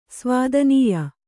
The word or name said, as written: ಸ್ವಾದನೀಯ